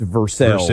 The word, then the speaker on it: Versailles: It's like Versailles being pronounced Versailles in Kentucky.